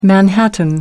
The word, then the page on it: manhattan: American English